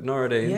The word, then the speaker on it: Nooruddean: I'm here with Nooruddean and Choudry.